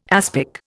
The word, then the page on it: aspic: Indian